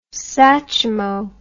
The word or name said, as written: Satchmo